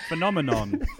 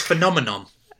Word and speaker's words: phenomenon: There's no M at the end of it. Phenomenon. – It's phenomenon.